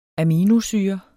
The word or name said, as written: aminosyre